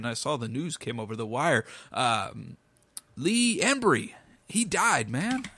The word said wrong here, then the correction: Ermey: Yes, I said the name wrong. Man, geez Louise, I'm a horrible person. But yeah, R. Lee Ermey.